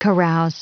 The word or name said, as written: carouse